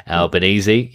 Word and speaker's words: Albanese: Albanese. – Albanese, yep.